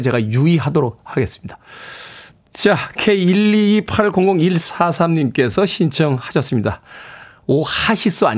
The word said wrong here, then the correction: 오아시스: 아니고요. 네, 오아시스의 whatever.